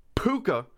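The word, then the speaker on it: púca: It's pronounced puka. Which is the Irish word for goblin, spirit, or sprite.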